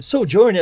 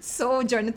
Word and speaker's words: Sojourn: Sojourn, é assim que se pronuncia, Mandinha? – Sojourn tá certo, tá perfeito.